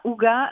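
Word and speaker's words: hygge: oui, alors en fait, c'est le ouga. Alors quand on dit ça, ouga, la plupart du temps, euh, les, gens ne voient pas du tout de quoi je parle mm.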